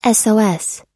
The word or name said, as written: sos